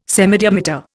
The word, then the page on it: semidiameter: Indian